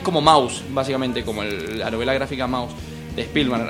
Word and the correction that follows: Spiegelman: Spiegelman, sí, Art Spiegelman.